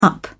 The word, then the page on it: up: British English